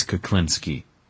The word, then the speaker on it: Kuklinski: My name is Kuklinski. It is spelled K-U-K-L-I-N-S-K-I.